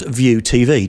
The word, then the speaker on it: VUTV: The service is spelt VUTV, which we think is pronounced View TV, don't we, Kelly? – We do. Well, we hope it is.